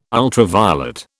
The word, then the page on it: ultraviolet: British English